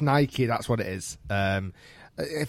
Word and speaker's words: Nike: Now, I know it's spelt like Nike, as in bike or Mike or whatever, but genuinely, it's named after the Greek goddess of victory, which was, I think, Nike, and it is called Nike.